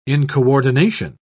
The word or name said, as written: incoordination